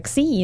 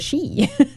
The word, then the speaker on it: Xi: Hey, Glenn, where were you when we were saying Xi instead of she? – We need some tutoring. – Come on. – Because that was the Greek pronunciation, not the Chinese one.